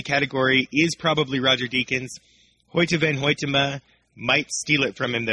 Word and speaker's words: Hoyte van Hoytema: His name is Hoyte van Hoytema, and I even looked up the pronunciation so that I could make sure to pronounce it correctly. It is Dutch.